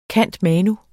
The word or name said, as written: cand.manu.